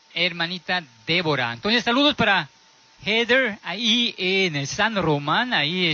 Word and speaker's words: Heather: Su amiga se llama Heather, hermano, Heather. No voy a confundir para decir Heather. Se llama Heather.